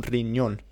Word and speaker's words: riñón: And we start with a phrase which I quite like, which is costar un riñón. – Costar un riñón. So you remember that costar means to cost. So you might say, eso cuesta un riñón. Un riñón, R-I-N-Y, which is that N with the funny little squiggle above it, pronounced near, O-N.